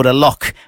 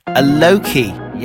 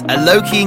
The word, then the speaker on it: Alok: I used to think this guy was called Alok, but I've since found out it's pronounced Aloki. Yes, Aloki and Khalid.